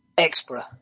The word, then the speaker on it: Xpra: You're gonna sit there and play with Xapra. What is that called again? – Xpra. It's how I pronounce it. – Xpra.